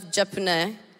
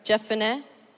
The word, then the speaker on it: Jephunneh: son of Jephunneh. Did I say that right? Jephunneh? It's fine.